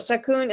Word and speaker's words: Shakun: So Shakun, am I pronouncing your name correctly? – Absolutely.